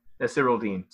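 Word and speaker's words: Nasirildeen: Hamza Nasiruddin. I butchered that. – That's literally, I think. – Yeah, Nasiruddin. So I took him.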